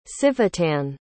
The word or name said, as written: civitan